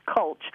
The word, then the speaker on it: culch: And until it attaches to a piece of shell called culch, rhymes with mulch, but it's culch,